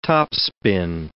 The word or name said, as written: topspin